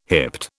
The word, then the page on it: hipped: British English